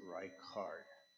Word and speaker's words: Reichard: And as far as the last name, we pronounce it Reichard. It's like rye seed or rye bread, and then a card. You know, you pull a card, Reichard.